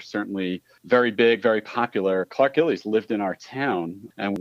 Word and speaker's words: Gillies: Yes, Clark Gillies from the legendary New York Islanders teams.